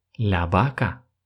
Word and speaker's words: vaca: Итак, el caballo – конь, la yegua – лошадь, символ Испании – el toro – бык, корова будет – la vaca. Начинается слово с буквы «V», но помним про то, что в Испании чаще всего эта буква читается как звук «Б». La vaca – петух.